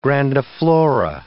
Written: grandiflora

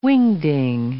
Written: wingding

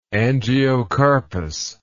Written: angiocarpous

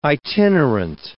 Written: itinerant

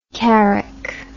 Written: carrick

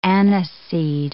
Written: aniseed

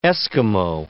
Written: Esquimau

